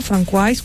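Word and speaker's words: François: El niño se llama Francois, como se pronuncia en francés.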